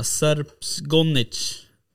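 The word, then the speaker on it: Srpski gonič: ja, ja, men du, um, vad tänkte på eh, den här andra rasen då, Serps gonnich? Ja. Uttalade jag det eh, rätt? – Ja, man kan ah, kalla det för serbisk stövare också, för Gomez betyder ju stövare egentligen.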